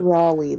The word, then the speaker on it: Raleigh: Raleigh, there you go.